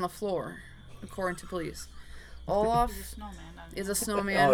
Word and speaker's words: Olaf: Meter grabbed an Olaf. Is that how you say it? – Mm-hmm. Oh, okay. – Olaf stuffed toy and proceeded to perform...